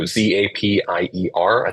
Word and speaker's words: Zapier: So Zapier, I think it's supposed to be happier, but with a zap.